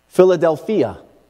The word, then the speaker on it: Philadelphia: Philadelphia is actually how it's pronounced in the Greek.